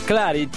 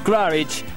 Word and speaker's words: Claridge: Martes 21 de abril, que es mañana, estará Ultra Tango para la gente de nivel en el Hotel Claridge. Claridge, se pronuncia así. Claridge.